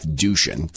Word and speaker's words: Duchin: What you see with Jay Inslee running Washington State with the assistance of so-called Dr. Jeff Duchin. That's, by the way, that's his name. Someone said earlier, oh, you know, it's amateur stuff to miss. That's his name. That's literally how his name is pronounced.